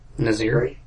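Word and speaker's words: Saint-Nazaire: Saint Nazir, how do you say mm-hmm. that? – Yeah. – How do you say it? – Nazir.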